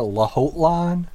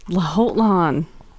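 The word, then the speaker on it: Lhotlan: I said a Lahotlan. – Lahotlan. – Oh, I like that emphasis on the H.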